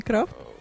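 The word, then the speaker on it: craft: Ну-ка еще раз вот это последнее слово произнеси. – Я не знаю, как оно произносится, но как слышится, так и произношу. Крафт, не крафт? – Крафт, правильно.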